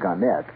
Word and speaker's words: Gannett: Gannett or Gannett, they prefer to pronounce it Gannett, Westchester newspapers.